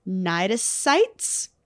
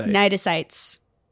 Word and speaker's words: cnidocytes: Cnidocytes. You nailed that. – Thank you. Because it's not spelled how you would expect it. It's spelled C-N-I-D-O-C-Y-T-E-S. – Oh, yeah. What's that C doing there? – It's just hanging out. – That has no business being at the front there.